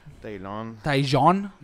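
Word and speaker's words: Taillon: Sí, empezaron con todos los, los cachorros, pero pues no, no ha podido ganar eh, Jameson Tilón. Taylon. – mejor dicho.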